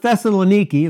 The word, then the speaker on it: Thessaloniki: It says, but when the Jews of Thessaloniki, and that's the way you properly pronounce it, had knowledge that the word of God was preached of Paul at Berea, they came there and stirred up the people.